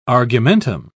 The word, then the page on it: argumentum: British English